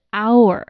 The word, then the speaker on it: hour: 但它的发音是 hour，这个 h 是不发音的，所以呢，它前面还是要用 an 而不是 a。